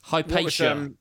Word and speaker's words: Hypatia: Hypatia is how you pronounce it.